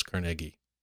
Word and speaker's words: Carnegie: It's Carnegie. – No. – Yeah. – I've never heard that in my life. – Okay. – I have. – That's how he pronounced it.